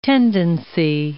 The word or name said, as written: tendency